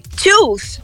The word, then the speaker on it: tooth: Foot é um som do U curto, foot. E tooth já é longo, que é igual good e book. E depois vai ficar food, que é longo.